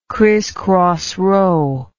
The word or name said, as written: christcross row